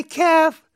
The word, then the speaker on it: calf: C-R-A-M-P. Cramp. I got a cramp. 나 쥐났다. 어디에? In my calf. 이게 C-A-L-F인데 L은 발음 안 하는 묵음이에요. calf가 아니라 calf인데 뭐가 그 식당 가면 뭐 아시는 분 아시겠지만 그 송아지 고기 할 때 calf를 쓰거든요.